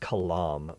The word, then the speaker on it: Kalam: I think it's actually pronounced Kalam, but I like to say Kalam. You know, God created the, God said Kalam, and there was a universe. No, that's not what it is. But let me explain this to you, because this is actually pretty cool. So the Kalam, and that's spelled K-A-L-A-M,